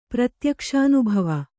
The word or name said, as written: ಪ್ರತ್ಯಕ್ಷಾನುಭವ